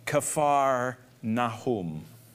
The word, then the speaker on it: Kfar Nahum: Many of you probably have heard of the city of Capernaum, or as they would pronounce it, Capernaum, or as the Hebrew pronunciation is, Kfar Nahum, which means the city of Nahum.